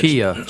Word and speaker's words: chia: Well, I don't know what they're other pronounced, but chia. – Chia seeds. – It looks like chai. – That sounds like what I'm thinking of. – It's chai with the I and the A switched around.